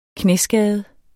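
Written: KNÆSKADE

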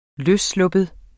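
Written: LØSSLUPPET

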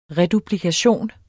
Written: REDUPLIKATION

